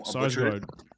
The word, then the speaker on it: Sarsgaard: Sarsgaard.